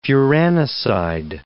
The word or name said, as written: furanoside